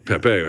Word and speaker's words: Pepe: Yeah, it's not P-E-P-E is Pepe, where I come from, but... – Pepe actually has an accent on the second E, so it's Pepe.